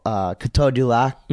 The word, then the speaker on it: Coteau du Lac: Montreal, Coteau du Lac.